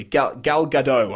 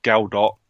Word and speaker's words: Gal Gadot: I'm assuming she's not like British, where it'll be Gal Dot.